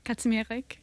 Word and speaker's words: Kazimierek: Kazimierek.